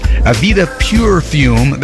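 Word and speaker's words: Aveda Purefume: Number 11 comes from Seal, and after shaving his head, this man puts Avita Pure Fume, that's the way it's pronounced, brilliant anti-humectant pomade all over his well-polished scalp.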